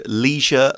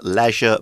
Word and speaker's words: leisure: And a quick note about that word leisure, leisure, both pronunciations are okay. Uh, leisure is more common in British English, I think.